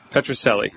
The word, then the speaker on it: Pitruzzelli: Petroselli.